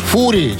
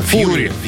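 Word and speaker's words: Fury: «Фури» читается, не знаю. – «Фьюри», «Фьюри», «Ярость».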